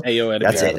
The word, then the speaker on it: Ayo Edebiri: I did want to bring up the, I'm going to butcher her name. It's Ayo Edebiri from The Bear who plays April O'Neil. – Ayo Edebiri. – That's it.